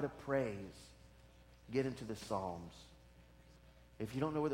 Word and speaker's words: Psalms: And it looks like Psalms, but it's really Psalms. It's a silent P there.